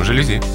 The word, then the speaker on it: жалюзи: Я вам советую купить все-таки жалюзи, потому что так и только так надо говорить это слово. Слово-то французское, ударение на последний слог, поэтому жалюзи.